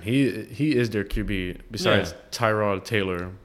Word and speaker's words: Tyrod: Tyrod. I think is how it's pronounced. They said last year. Tyrod. Yeah, in an interview. – Then why is there a Y? – I don't know. – That makes no sense. – Last year, in like an interview, like he said it or someone else said it, and they're like, oh yeah, it's pronounced Tyrod. We just never correct you guys.